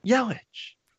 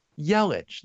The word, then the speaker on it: Yelich: How do you say it? – Yelich. – Huh? – Yelich, the way literally everybody else.